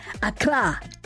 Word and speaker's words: Accra: Lots of love to Ebenezer, my brother Ebenezer, listening in Accra. Did I say that right? Accra.